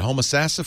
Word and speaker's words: Homosassa: And Bill is on the line from, all right, Bill, tell me how you say it, Homosassa, Florida? Did I get that right? – You got it totally correct.